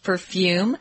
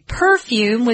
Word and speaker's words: perfume: Some people say perfume with the accent or stress on the first syllable. And then you can also say perfume, which is closer to the original French word.